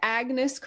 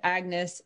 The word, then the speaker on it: Agnis: My character is Agnis Crown Silver. She requires that you pronounce it Agnis, not Agni, like her pretentious royal family does she does not hold with the royal tradition that she was raised in.